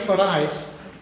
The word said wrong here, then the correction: Farias: Farias, excuse me, I mispronounced that.